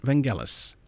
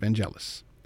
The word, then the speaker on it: Vangelis: The score by Vangelis, and yes, I have it on good authority that it is pronounced Vangelis, not Vangelis, is a dark combination of classic composition and futuristic synthesizers, which mirrors the film noir retro future envisioned by Ridley Scott.